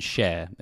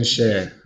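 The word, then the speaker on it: Schär: I'm trying not to butcher his name, but I believe it's pronounced Fabian Share. It's like the single. – Fabian Share.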